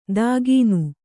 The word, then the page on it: ದಾಗೀನು: dāgīnu